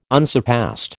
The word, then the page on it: unsurpassed: American English